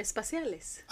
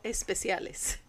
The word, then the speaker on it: espaciales: Espaciales. – I thought that meant special. – Latinas espaciales, no especiales. Espaciales for space.